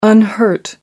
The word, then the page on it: unhurt: American English